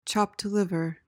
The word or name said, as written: chopped liver